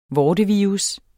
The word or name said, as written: vortevirus